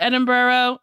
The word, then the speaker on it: Edinburgh: um, so Tej, Roman, and Ramsey head to Edinburgh. Is it Edinburgh? Am I saying that right? – Yeah.